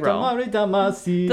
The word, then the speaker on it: Katamari Damacy: But yeah, so I want to do Katamari Damacy. Is that how you say it? – Damacy. – Damacy reroll. – Katamari Damacy. – Damacy. Yeah. Yeah, sorry.